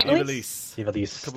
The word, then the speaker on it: Ivalice: They had the return to Ivalice or whatever. I don't even know how to fucking pronounce it right now. – Ivalice.